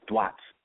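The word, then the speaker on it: Duat: D is spelled with a T, but it's pronounced dwat.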